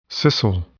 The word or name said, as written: scissile